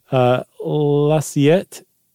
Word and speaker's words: l'assiette: How are you going to pronounce that in French? – L'assiette. – Yeah, l'assiette. That sounds about right.